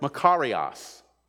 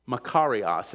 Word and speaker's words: makarios: It's, it's the Greek word makarios, makarios, and it's spelled M A K A R I O S, makarios.